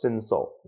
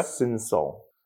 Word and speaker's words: Cinsault: I probably said that wrong, but. – Sancerre? – No, it's Cinsault. – Okay. – Cinsault.